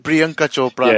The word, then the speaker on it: Priyanka Chopra: Priyanka Chopra.